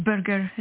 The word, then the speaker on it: Burger: Is it Berger? Did I say that correct? – You know, it's Berger. The forefathers were German and Dutch.